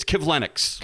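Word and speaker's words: Kivlenieks: I'm afraid to mispronounce the guy's name. He's 24-year-old Matisse Klevlenieks. Klevlenieks? – Well, because I prepare for the show, I watch a YouTube clip of him. – Oh, okay. – It's Klevlenieks.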